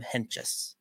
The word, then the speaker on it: Hentges: But, hey, good for Sam Hentges, man. I found out that's how you say it, by the way. I don't have to call him Sam H anymore. It's fucking Sam Hentges.